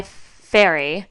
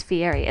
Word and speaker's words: Fieri: His original name is Ferry, like ferry. – Oh. – Like a ferry boat. Like spelled like a ferry boat. – Yeah. – And it was Guy Fieri. And he, like, when he became famous, changed it to Fieri just to make it sound more like blue, blue, blue.